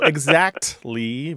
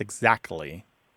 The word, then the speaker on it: exactly: Look, there's two common pronunciations of this word throughout the English-speaking world, no matter where English is spoken. And in your household, they both exist. Exactly with a T and exactly without the T sound.